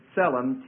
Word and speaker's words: tselem: The word for image in the Hebrew is tselem, T-S-E-L-E-M. Tselem. They pronounce both the T and the S in Hebrew. There's one letter in Hebrew that is tzadi, that has that sound.